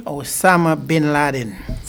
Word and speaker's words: Osama bin Laden: Osama bin Laden.